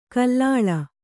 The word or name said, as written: ಘಲಿಲು